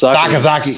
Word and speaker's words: Sakazaki: Like, and it's Sakazaki. Sakazaki. – Sakazaki.